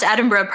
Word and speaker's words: Edinburgh: And by the way, you pronounced Edinburgh perfectly, and I was really impressed.